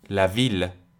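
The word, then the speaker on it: ville: مثلا کلمه شهر لویل این استثنا اینجا دارم لش رو تلفظ میکنم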